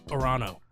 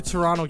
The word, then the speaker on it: Toronto: You like Toronto, you would know they don't pronounce the T in Toronto. It's Orano. No, it's just Toronto.